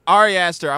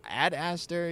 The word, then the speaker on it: Ari Aster: Ari Aster. I wanted to talk to somebody about Ari Aster, but I really couldn't say his name. I'm like, Ad Aster, you know, and I didn't have bars, so I couldn't look his name up.